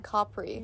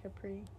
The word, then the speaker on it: Capri: And so the first day we went to Florence, like we mentioned, the second day we went to Naples and Capri, or I keep mispronouncing it. It's like Capri. – Yeah. I think Americans say Capri.